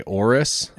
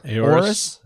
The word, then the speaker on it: Aorus: I would just say Aorus. – Aorus. A-O-R-U-S.